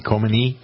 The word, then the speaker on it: Khomeini: Is that how you pronounce his name? Khomeini? – Yeah.